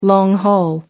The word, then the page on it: long haul: American English